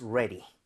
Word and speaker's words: ready: 앞에 re는 r 살아있기 때문에 모양 모으고 혀 당겨서 re로 가고요. D 할 때는 flap을 내서 리을 비슷하게 발음해주세요. 리을 비슷하게. So, ready.